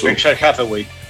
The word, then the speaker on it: Berkshire Hathaway: Berkshire Hathaway. – Berkshire Hathaway.